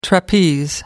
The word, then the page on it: trapeze: American English